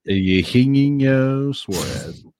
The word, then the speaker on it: Eugenio Suarez: Eugenio Suarez. That's how they say it.